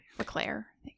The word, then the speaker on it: Leclerc: Leclerc, yeah.